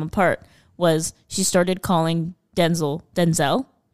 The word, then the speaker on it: Denzel: Has revealed that his real name, the way that you pronounce it, is actually Denzel. – Denzel.